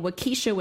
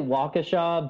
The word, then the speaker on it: Waukesha: Did I pronounce that right? – It's actually Waukesha, but hearing East Coast people trying to pronounce, you know, city names in Wisconsin is always fun. So maybe we should just leave that one on there. But it's, it's in Waukesha, so small town Wisconsin.